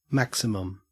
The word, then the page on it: maximum: Australian English